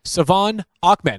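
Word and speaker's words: De'Von Achane: I've completely butchered his name a couple of times up to this point. Savon Ahmed. And I apologize for that. That's how you pronounce it properly. It's not phonetic, so to speak.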